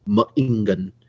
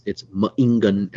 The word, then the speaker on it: ma'ingan: And so in Ojibwe, one of the better examples of that is the word for wolf, which is ma'ingan. And it's ma'ingan as a pause in the middle of that pronunciation.